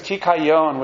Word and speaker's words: kikayon: It's kikayon, which sounds like vayake et yonah, when the fish spewed out yonah.